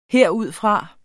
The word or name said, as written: herudfra